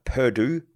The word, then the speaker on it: Perdoo: If you haven't read the book Measure What Matters by John Doerr, or you can go and look up Perdue, which is spelled P-E-R-D-O-O. So P-E-R-D-O-O, Perdue.